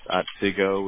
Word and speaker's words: Otsego: And that's Otsego, which is spelled OTSEGO.